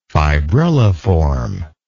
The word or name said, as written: fibrilliform